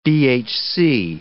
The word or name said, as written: BHC